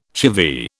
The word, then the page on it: chivvy: British English